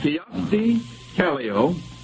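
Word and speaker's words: Kyösti Kallio: I'll repeat that. Kiyosti Kallio, I'll spell that, K-Y-O-S-T-I-K-A-L-L-I-O, is a Greek island, a dictator of Peru, president of Finland, or the name of a Japanese political party?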